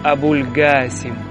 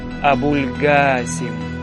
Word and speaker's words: Абуль-Гасім: А якщо людина не знає, як правильно вимовляти слово «Мухаммад», то може сказати інше ім'я пророка Мухаммада, наприклад, «Абуль-Гасім» з проривною «Г» і протяжною «А». «Абуль-Гасім».